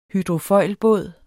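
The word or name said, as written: hydrofoilbåd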